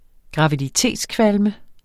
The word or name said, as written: graviditetskvalme